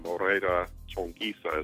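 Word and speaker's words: Moreira Chonguiça: A friend of mine, famous saxophone player by the name of Moreira Chonguisa, is how he says how you say his name. – Wow. – In Portuguese.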